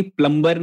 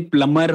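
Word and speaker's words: plumber: प्लम्बर नहीं होता है एक्चुअली प्लमर होता है तो मुझे आज तक पता नहीं था मेरे को भी अच्छा बी बी साइलेंट होता है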